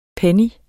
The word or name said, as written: PENNY